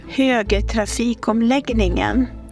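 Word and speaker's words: Högtrafikomläggningen: And here's how that word is actually pronounced by a real Swede. – Högtrafik omlagningen.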